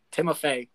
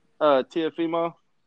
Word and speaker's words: Teofimo: For sure, I want to see Timofey. – Teofimo. – Yeah, how you say his name? – Teofimo.